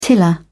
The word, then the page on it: tiller: British English